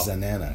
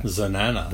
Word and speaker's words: Xanana: The choir, it's spelled X-A-N-A-N-A, like banana with an X at the beginning. – Wow. – Zanana. – Zanana.